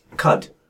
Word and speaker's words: Qud: I'm playing a game called Caves of Cud, which is spelled Quad, Q-U-D.